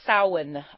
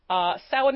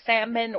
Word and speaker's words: Samhain: Halloween comes from All Hallows' Eve, but prior to that, it was the festival of Samhain on the Celtic calendar. Samhain is spelled S-A-M-H-A-I-N, so you will sometimes hear people say Salmon or Samhain,